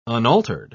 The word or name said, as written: unaltered